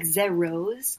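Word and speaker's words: ZEROSE: Because I think the way to pronounce it is like zeros, like roses, like the flower roses.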